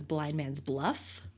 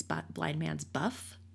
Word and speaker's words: Blind Man's Buff: My whole life I thought it was Blind Man's Bluff. Apparently it's Blind Man's Buff.